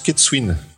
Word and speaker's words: Ketsuin: Oh, euh, Non, c'est comme Gwendar, ça se prononce Ketsuin.